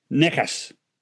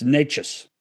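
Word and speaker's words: Nečas: So as soon as I know that, then I know it's not Nickus. It's Natchez.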